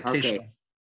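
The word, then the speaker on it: Ke'Shawn: How you say his name? Kayshon Vaughn? – You got it, Kayshon.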